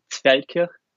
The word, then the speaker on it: Zfeldkirch: Ah ja, das ist im Dialekt und das heißt Zfeldkirch, also in Feldkirch, behind the postcard, genau.